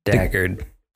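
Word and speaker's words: Dacre: You know, Luke says, first Jeremy, who's played by Joe, please pronounce his first name. – Well, no problem there. Decray? – Dackard. – Dackard? – Dacker? – Dackard.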